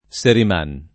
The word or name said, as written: Seriman